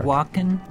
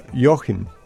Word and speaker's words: Joachim: K-U-H-N. How do you say that? El Dorado was the track. Was it Joaquin? – Huh? – Joaquin, you said? – Joachim. – Oh, Joachim.